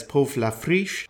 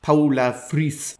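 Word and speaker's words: Povla Frijsh: I've always known her as Puf La Frisch. I was corrected by my Danish colleague. Her name is actually pronounced in Danish Paula Fris, or something like that.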